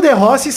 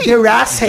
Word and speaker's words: De Rossi: Sem o De Rossi e sem o Tevez.